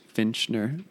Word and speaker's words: Fincher: It's by David Finchner. He's the director. Fincher. David Fincher. I'm bad at pronouncing that.